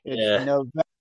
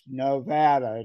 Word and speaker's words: Nevada: It's like Nevada or Nevada, right? Nevada. They want it pronounced Nevada. It's not Nevada.